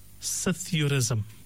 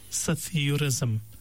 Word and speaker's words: psithurism: But its spelling is very surprising because it's spelled with a P, but the P is totally silent. – Right. – So it's spelled P-S-I-T-H-U-R-I-S-M. So it's P-S-I-T-H-U-R-I-S-M. It is pronounced, the P is silent, so it's pronounced as Sithurism.